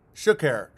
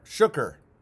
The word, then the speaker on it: Shuker: It's pronounced like sugar, but with a K sound in the middle. Shuker, Shuker.